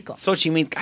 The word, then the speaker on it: Xochimilco: Se llama el Xochimilco. ¿Lo dije bien? – Xochimilco.